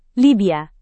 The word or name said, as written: libya